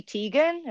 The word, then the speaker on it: Teigen: Chrissy Teigen. Is that how you say her name? Uh-huh.